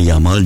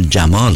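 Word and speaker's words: Yamal: فامیلش رو به جای یامال جمال تلفظ میکنن، جمال.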